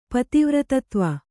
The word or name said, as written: ಪತಿವ್ರತತ್ವ